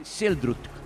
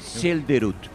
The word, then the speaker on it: Selderut: Por ejemplo, aquí Selderut se escribe, pero dice Seldrut. Seldrut. No dice Selderut. Y es más fácil porque es más corto.